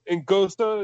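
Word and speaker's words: Anguissa: I'm sorry. Ngosa is how it's pronounced.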